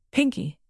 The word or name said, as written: pinkie